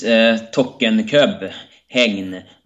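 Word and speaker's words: Tokkekøb Hegn: Illustration: the king's dolmen took Tokakob, Hegn, Northern Zealand, Denmark. How's that pronounced? Tokakob Hegn. – It is Tokenkøb, Hegn, Northern Zealand in Denmark, and Zealand is the island where Copenhagen is located.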